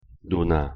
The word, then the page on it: 누나: Korean